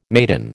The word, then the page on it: maidan: American English